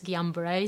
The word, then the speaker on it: Guillain-Barré: I heard it as Guillain-Barré syndrome.